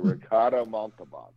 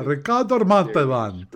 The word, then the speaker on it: Montalban: So, and there was also much discussion about Ricardo, and I can't pronounce it. I cannot read it in my R's the way he does. But Ricardo Montalban. – Ah, Ricardo Montalban.